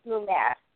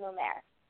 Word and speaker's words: Mummert: The last name actually is, the T is silent, and it's Mummert. So it's Lara Jean Mummert is my name.